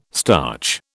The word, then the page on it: starch: British English